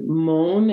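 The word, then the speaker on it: Maun: And so in Moun, M-A-U-N, I think it's pronounced,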